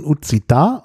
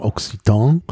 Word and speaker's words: occitan: Also stand dort äh, das Wort, also richtig ausgesprochen, Uzita, also auf Deutsch würde man vielleicht sagen Occitan, äh, also Occitan geschrieben, äh, O-C-C-I-T-A-N.